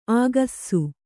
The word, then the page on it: ಆಗಸ್ಸು: āgassu